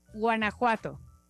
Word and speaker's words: Guanajuato: Okay, uh, so it's gua. – Gua. – Na. – Na. – Ku. – Ku. – A. A. To. Guanajuato. – Guanajuato.